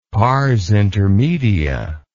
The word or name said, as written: parsintermedia